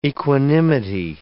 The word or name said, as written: equanimity